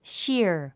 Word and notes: sheer: ʃíər; シア

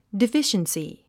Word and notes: deficiency: difíʃənsi; ディフィシェンスィ

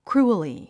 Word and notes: cruelly: krúːəli; クルゥエリィ

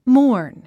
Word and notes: mourn: mɔ́ːrn; モォーン

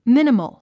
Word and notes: minimal: míniməl; ミニモゥ

